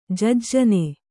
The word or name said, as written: ಜಜ್ಜನೆ